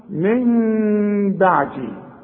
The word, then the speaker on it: من بعدي: واسمع نطقها من بعدي، ما اقولش من بعدي واقولها نون، ولا اقولها ميم خالصة واقول من بعدي، يحصل انفراد في الشفايف.